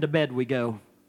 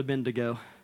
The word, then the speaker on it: Abednego: My great grandma Ada Reynolds, she said, Shadrach, Meshach, and to bed we go. That's how she pronounced Abednego.